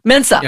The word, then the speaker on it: Mensa: You're not going to become a Mensis Mensa. – Yeah. I knew I wasn't saying that right.